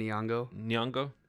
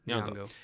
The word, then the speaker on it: Nyong'o: how's her last name pronounced? – Lupita Nyong'o. – Nyong'o.